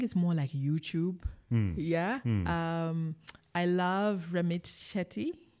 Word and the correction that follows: Sethi: Ramit Sethi.